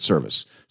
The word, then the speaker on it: Servais: I think they pronounce it Service.